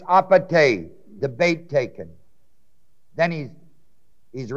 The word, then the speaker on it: apate: Then there's another Greek word, apate, A-P-A-T-E, long E, which is deceit when the bait is taken.